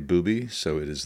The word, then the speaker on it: Bube: The name is Bubi. It's spelled B-U-B-E. It's a German name, and a lot of German names end in E, and when they do, you pronounce the E. So it's very confusing for most people in English to say it right.